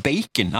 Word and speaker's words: bacon: De ville at vi kunne, skulle kunne skrive ordet bacon, altså B-A-C-O-N. Mm. Kjøttet. De ville jo at vi skulle skrive sånn som det sto. Ble uttalt B-E-I-K-E-N. Og det ble ikke tillatt.